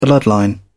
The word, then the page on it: bloodline: British English